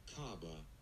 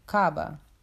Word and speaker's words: Kaaba: Kaaba. – Kaaba.